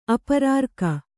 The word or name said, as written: ಅಪರಾರ್ಕ